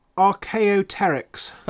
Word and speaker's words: Archaeopteryx: I'd say it was Archaeopteryx.